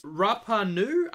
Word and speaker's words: Rapa Nui: Rapa Nui, I think is how you say this film's name.